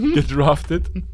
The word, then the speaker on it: gedraftet: war, der hat, der wurde nicht gedwarft, gedwarft, gedraftet, gedwarft. Er hat das eher falsch ausgesprochen, gedwarft. Gedwarft, ich war, ich war gerade bei Zwerg.